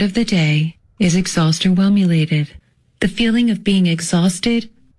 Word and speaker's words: exhausterwhelmulated: It's spelled E-X-H-A-U-S-T E-R-W-H-E-L-M U-L-A-T-E-D. – Exhaustionated.